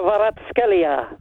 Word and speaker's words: Kvaratskhelia: Kvaratskhelia. There you go, that's how you say it. – Try it one more time. – Kvaratskhelia. – You couldn't do it twice. – Kvaratskhelia.